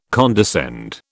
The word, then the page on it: condescend: British English